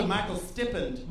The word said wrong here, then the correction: stipend: Am I pronouncing that right? I've only read that word before. – Stippend. – Stippend. – Who can say Stippend? Say it out loud if you know it. Stipend! Oh, right.